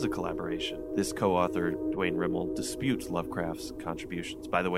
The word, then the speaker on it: Rimel: That was the opening of The Disinterment by Dwayne Rimmel. I hope I'm pronouncing that right. Is that right? – Yeah.